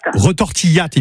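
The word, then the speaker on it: retortillat: Retortillate, il faut prononcer le T.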